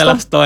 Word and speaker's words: Celasto: Oh iya, saya mau tanya ini, kenapa namanya Celas, bacanya Celasto? – Celasto ya.